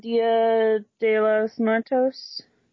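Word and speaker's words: Día de los Muertos: And what's the, it's a Dia de los Muertos? – Day of the Dead in Spanish, yeah. – Yeah, did I say it right? – Basically.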